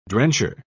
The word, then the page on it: drencher: British English